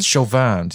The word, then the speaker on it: Chauvin: And they said, nah, it became pretty evident that Chauvin, I don't fucking know how to say it. It's a French name. It's Chauvin. Fucking idiots.